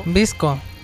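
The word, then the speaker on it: VSCO: Y ahora, ¿cómo se pronuncia esta aplicación? Visco, Vesco. – ¿Ah? – Visco, Vesco. – Visco. – Así se pronuncia.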